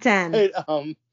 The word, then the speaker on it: Titane: Titane.